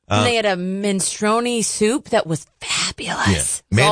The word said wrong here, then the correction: minestrone: the minestrone? – Minestrone.